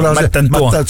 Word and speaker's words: Mastantuono: Lo chiamano Mastantuono? – No, lo chiamano, ma c'hanno tutta una pronuncia tutta loro. – Mattantuono? – Sì, bravo, tipo Mattantuono.